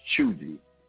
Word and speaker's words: cheugy: It's called Chuji. It's spelled C-H-E-U-G-Y. And it's pronounced Chuji.